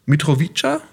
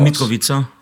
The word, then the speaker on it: Mitrovica: Also, du bist 1980 geboren in Mitrovica, spricht man das so aus? Mitrovica. – Mitrovica, sorry.